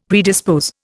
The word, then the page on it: predispose: Indian